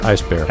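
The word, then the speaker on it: Eisbär: It just does not look like it would say Ice Bear. E1SBAR. Elsbar. – It is, but it's pronounced Ice Bear.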